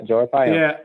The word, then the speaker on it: Arpaio: I'm going to mispronounce his name. Arpaio. – Yeah, that's it. Yeah, Joe Arpaio.